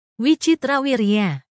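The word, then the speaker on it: Vichitravirya: Pandu was the son of Bayasa, a revered sage like Ambalika, the widow of Wikitrawira. I'm so sorry if I butchered that. Let's take a look. See if we can get that pronounced. – Wichitrawirya. Wichitrawirya. – Wichitrawirya. That one's hard to pronounce.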